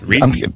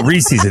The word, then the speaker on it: Reese's: Reese. – Yeah. – It's not Reese's. It doesn't rhyme with feces, Nate.